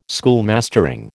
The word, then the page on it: schoolmastering: American English